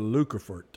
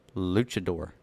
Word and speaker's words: luchador: Who came up with the name for the Lucafort? – Luchador.